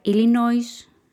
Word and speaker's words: Illinois: O sea que si ustedes quieren pronunciar Illinois en español es correcto, aunque en inglés la S no se pronuncia.